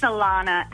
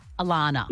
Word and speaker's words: Alana: It's Alana. And it's I get double N-A-H. I get every letter of the alphabet put at the end of it, but it's just A-L-A-N-A.